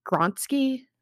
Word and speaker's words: Julie Gronsky: So Kane shows the tube to another resident in the hospital, Julie Gronsky. I hope I'm saying that right. It's a very like Gronsky. – Mm-hmm. – Yeah. Yeah. – It sounds correct.